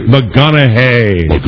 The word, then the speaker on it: McConaughey: Matthew McConaughey.